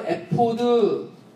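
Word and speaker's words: 에포드 (אֵפוֹד): 자, 에봇은 히브리어로 에포드 이렇게 발음하는 단어예요. 에포드. 이걸 에봇 이렇게 한글 개역 성경은 그대로 발음 나는 대로 기록을 한 거죠.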